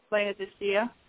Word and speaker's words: Laodicea: Well, yeah, we can take a break, but I just, I thought, and I'm not sure how to pronounce this, Laodicea? – Laodicea.